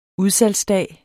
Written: udsalgsdag

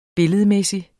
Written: billedmæssig

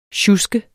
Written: sjusken

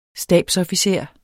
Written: stabsofficer

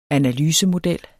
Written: analysemodel

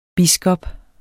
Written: biskop

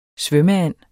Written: svømmeand

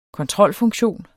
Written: kontrolfunktion

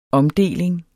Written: omdeling

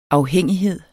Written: afhængighed

